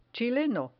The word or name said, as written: chileno